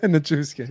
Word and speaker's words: Nichushkin: I think that they're probably going to lose Nichushkin. I'm so bad at pronouncing his name. But Nichushkin.